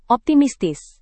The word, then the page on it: optimistis: Indonesian